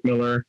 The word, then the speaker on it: Millar: no, no, it's Mark Millar. No, it's not. It's Mark Miller. He said his name is Mark Miller. It's Mark Miller. It's spelled M I L L A R, but it's Miller.